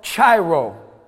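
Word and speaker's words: Chairo (χαίρω): This Greek word, rejoice in the Greek, is this word called chairo. C-H-A-I-R-O.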